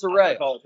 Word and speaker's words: Saraya: I still don't know how to say it. And it's Saraya.